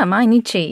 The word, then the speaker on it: Mainichi: Am I saying that correctly? Mainichi? – Mm-hmm. Yeah, Mainichi, yeah.